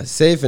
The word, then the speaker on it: Seaf: But it's spelled S-E-A-F, right? – Yeah, safe in Arabic stands for sword. So it's not, swords? – Yeah, it's not safety.